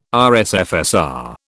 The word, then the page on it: RSFSR: British English